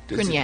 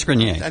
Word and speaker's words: Grenier: Is it Grenier or is it Grenier? – Grenier. – I think it's Grenier. – I know that's the French pronunciation, but I don't know whether he Americanized it or not.